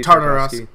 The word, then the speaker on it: Tartakovsky: It's Tartosky, baby. I think that's how you pronounce his name. – I think so. It's Genndy Tartakovsky. Genndy Tartakovsky.